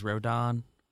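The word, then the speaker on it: Rodón: I just checked the baseball reference pronunciation, and it is Rodon.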